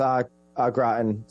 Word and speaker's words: au gratin: So my final pick here, the classic potatoes au gratin.